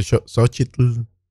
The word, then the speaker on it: Xochitl: La L, la T, L y Xochitl. – La TL, exacto. Xochitl.